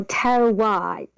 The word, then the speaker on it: terroir: Did you say terroir? – Oh, terroir. It's a French. So T-E-R-R-O-I-R.